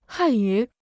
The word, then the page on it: 汉语: hàn yǔ